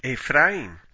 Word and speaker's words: Ephraim: In Hebrew, one could express double portion with the dual construction pi shanaim, which rhymes with Ephraim.